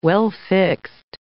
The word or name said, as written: well-fixed